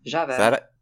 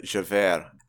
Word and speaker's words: Javert: Well, he does it on Javert? – Javert. – Javert? – I feel like you don't pronounce the T in French.